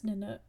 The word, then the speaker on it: Nanook: Anyways, uh, na- is it Nanok or Nanook? I think it's Nanok. – Um, I think it's pronounced Nanook. – Nanook.